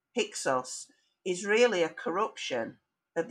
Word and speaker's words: Hyksos: And over time, that became, uh, you know, when the Greeks took over Egypt, looking back at Egypt's history, they pronounced Heka Hasut as Hyksos with the Greek ending.